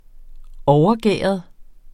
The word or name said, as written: overgæret